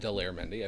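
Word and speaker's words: Daler Mehndi: There was this Indian gentleman named Dollar Mendy who did, it's actually pronounced Dollar Mendy, I've since found out.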